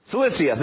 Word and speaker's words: Cilicia: Cilicia. How do you say that? Anybody know? Cilicia, thank you.